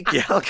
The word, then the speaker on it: gyal: And gyal is spelled G-Y-A-L. It's gyal, come on to me.